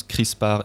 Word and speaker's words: CRISPR: Je vous préviens tout de suite que moi je prononce CRISPR et pas CRISPR. Donc, CRISPR, C-R-I-S-P-R, c'est un nouvel outil de biologie moléculaire qui ouvre la voie à la modification du patrimoine génétique in vivo.